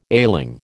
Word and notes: ailing: American English

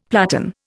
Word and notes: platan: Indian

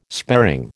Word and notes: sparing: American English

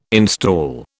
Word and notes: install: British English

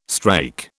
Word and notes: strake: British English

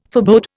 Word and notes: forebode: Indian

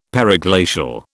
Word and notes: periglacial: British English

